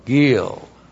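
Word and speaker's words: Gil (גיל): G long I L, Gil.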